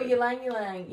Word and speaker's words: Ylang Ylang: Jasmine and Lang Lang. I'm going to butcher that, but it smells so good. – Oh, Ylang Ylang.